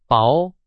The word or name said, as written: 薄